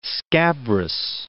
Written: scabrous

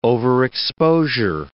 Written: overexposure

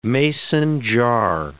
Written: mason jar

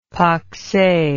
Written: pakse

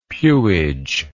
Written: pewage

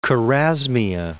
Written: Chorasmia

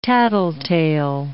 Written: tattletale

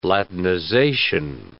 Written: latinization

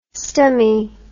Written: stomy

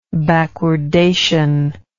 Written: backwardation

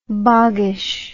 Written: boggish

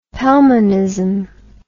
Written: Pelmanism

